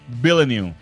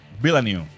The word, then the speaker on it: Villeneuve: Mira, la pronunciación es Villeneuve. Villeneuve.